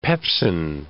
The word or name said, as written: pepsin